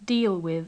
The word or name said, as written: deal with